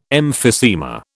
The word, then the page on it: emphysema: British English